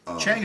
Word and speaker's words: Chang: I mean, the other major Thai one that Americans tend to be familiar with is Chang, I think. – Yeah. – Which I'm not even sure I'm pronouncing that right. But Chang is okay.